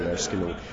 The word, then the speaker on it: Erskine: Just typing in my name, Gary Erskine, I'm sure they'll find it. And E-R-S-K-I-N-E. – Yes, so it's a silent E.